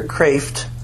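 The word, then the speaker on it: Kreeft: It's by Dr. Peter Kraft, and that's pronounced Kraft, not Kreef.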